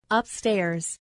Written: upstairs

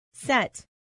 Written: set

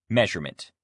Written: measurement

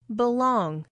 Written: belong

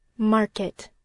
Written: market

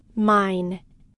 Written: mine

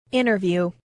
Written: interview

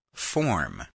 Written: form

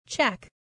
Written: check

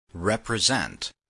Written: represent

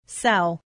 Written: sell

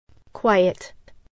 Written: quiet

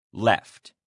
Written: left

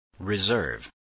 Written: reserve

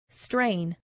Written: strain

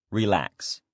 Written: relax

